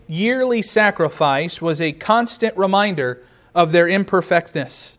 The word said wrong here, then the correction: imperfectness: I emphasized the wrong syllable there. It was a reminder of their imperfectness. There we go.